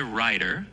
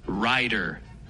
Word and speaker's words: rider: La palabra jinete en inglés se dice rider y se pronuncia más o menos así, rider. Es como si dijeras rai y luego der. Súper sencillo.